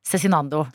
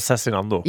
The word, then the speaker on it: Cezinando: For det er, det er sånn det uttales. – Cezinando. – Det er Cezinando.